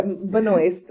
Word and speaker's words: Benoist: Benoist is how you pronounce it.